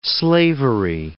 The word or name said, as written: slavery